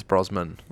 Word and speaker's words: Brosnan: Pierce Brosnan. – Brosnan, yeah. – Yeah, say it right, man. Brosman. – Yeah. I'm confused from early childhood.